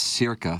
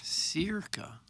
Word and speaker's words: Circa: Hey, Tim, I think it's pronounced Circa. Thanks. That's from Big Tuft. – Circa. – Yeah, we heard that. – Uh-oh. – Jackson cleared it up as Circa.